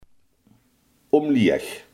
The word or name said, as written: omlaag